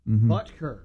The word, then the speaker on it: Butker: How do you say his name? Butker? – Butker. – Butker? – Yeah.